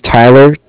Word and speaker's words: Tyler: My name is Tyler.